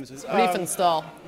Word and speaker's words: Riefenstahl: Riefenstahl. – Riefenstahl, thank you.